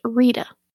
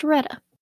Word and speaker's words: Reta: Back to my southern coworkers, I had an argument with one of them that her name is pronounced Rita. I disagree. I think it is pronounced Reta. It is R-E-T-A.